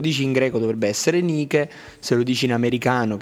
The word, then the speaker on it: Nike: è Nike.